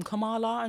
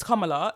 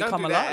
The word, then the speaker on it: Kamala: And one of the comedians was like, yeah, I can't say her name, Kamala. And she was like, it's Kamala. Like, don't be like the white people. Like, just say Kamala.